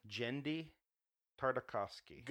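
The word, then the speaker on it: Genndy Tartakovsky: Jenedy Tartakovsky.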